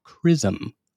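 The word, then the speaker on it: XRISM: The X-ray Imaging and Spectroscopy Mission, X-RISM for short, and pronounced like CRISM, is the launch's primary passenger from an orbit 350 miles above the Earth.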